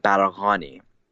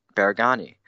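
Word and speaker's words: Baraghani: My last name, the correct pronunciation, which is also very difficult to say, is Baragani. The GH makes the G sound, which is actually very hard to pronounce if you don't speak Farsi.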